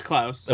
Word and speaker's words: Klaus: Is that how you say it? Is that, or is it Claus or Klaus? I think the character refers to him as Klaus at one point. – Yeah, it's Klaus.